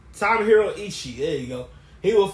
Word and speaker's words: Tomohiro Ishii: Tomohiro Ishii.